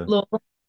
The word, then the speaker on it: Louisville: And you say Louisville beautifully too. It's you got the Louisville. – Got the pronunciation down.